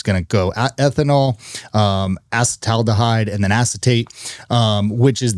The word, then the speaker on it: acetaldehyde: And then it's going to convert that ethanol to, I'm going to mispronounce this, acetaldehyde, which is a toxic, did I say that right? – I think so.